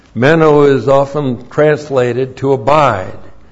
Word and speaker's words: meno: That's the word meno, and with John, he has a couple of words he uses over and over and over again.